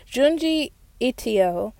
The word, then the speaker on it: Junji Ito: Now, if you don't know who Junji Ito is, I said his name wrong the first time, but Junji Ito, he's an amazing horror manga artist.